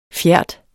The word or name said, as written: FJERT